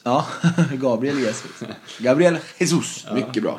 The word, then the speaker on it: Jesus: Gabriel Jesus i City. – Ja. – Som snyggt uttalade, annars får ni med mejla. – Ja, nej, Jesus, helt korrekt.